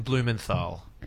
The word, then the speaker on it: Blumenthal: Yes, Heston Bluthamol. Heston. Yes. Did I absolutely butchered that. – Heston Blumenthal.